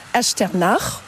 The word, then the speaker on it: Echternach: Vous nous souhaitez la bienvenue à Echternach. Est-ce que je prononce bien ? – Très bien prononcé. Echternach, oui, comme on dit, ou Echternach en luxembourgeois.